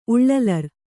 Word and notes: ಉಳ್ಳಲರ್: Kannada

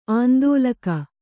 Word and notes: ಆಂದೋಲಕ: āndōlaka

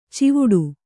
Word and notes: ಚಿವುಟು: civuṭu

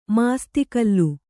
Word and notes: ಮಾಸ್ತಿಕಲ್ಲು: māsti kallu

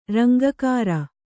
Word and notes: ರಂಗಗಾಱ: kannada

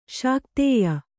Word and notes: ಶಾಕ್ತೇಯ: Kannada

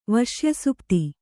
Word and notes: ವಶ್ಯಸುಪ್ತಿ: vaśya supti

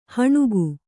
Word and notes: ಹೆಣಿಗೆ: Kannada

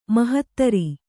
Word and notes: ಮಾಹತ್ತರ: kannada

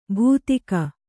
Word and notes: ಬೂದಿಗ: būdiga

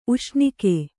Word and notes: ಉಷ್ಣಿಕ್ಕು: uṣṇikku

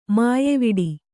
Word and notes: ಮೆಯ್ವಿಡು: meyviḍu

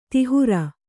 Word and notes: ತಿಹುರ: tihura

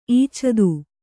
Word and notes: ಇಚ್ಚಿತ್ತ: kannada

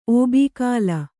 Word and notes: ಓಬೀಕಾಲ: Kannada

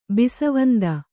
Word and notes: ಬಿಸವಂದ: bisvanda

